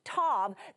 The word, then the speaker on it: tav: That word is what is T-A-W, but pronounced Tav.